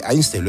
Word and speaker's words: Einstein: Sí, sí, Einstein, lo he dicho bien.